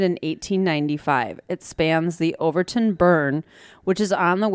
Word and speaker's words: Overtoun: The Overton Bridge, and I hope I'm pronouncing that right. It's spelled O-V-E-R-T-O-U-N. – Overton Bridge? Overton Bridge? – Just say Overton.